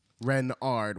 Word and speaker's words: Renard: Renard with the accent on the Ren.